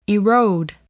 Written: erode

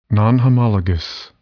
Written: nonhomologous

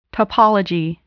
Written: topology

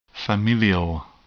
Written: familial

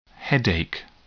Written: headache

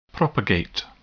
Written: propagate

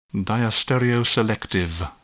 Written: diastereoselective

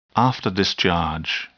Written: afterdischarge

